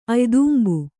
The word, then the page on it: ಅಯ್ದುಂಬು: aydumbu